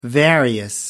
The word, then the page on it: various: American English